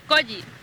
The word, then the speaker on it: Colli: Colli. – Colli. ¿Cómo se escribe? – C-O-L-L-I.